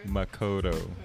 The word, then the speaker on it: Makaurau: where it's a collective of indigenous queer artists here, based here in Tamaki Makaurau. And correct, I think my cousin will correct if the I pronounced it incorrectly. – Makaurau. – Yeah, there we go.